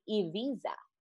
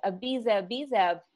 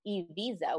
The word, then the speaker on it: Ibiza: Second thing is the correct pronunciation of the island's name is actually Ibiza. So we're used to saying it as Ibiza, but in how they pronounce it over there, it's Ibiza with an E.